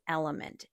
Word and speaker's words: LMNT: And that is Element. It is spelled L-M-N-T.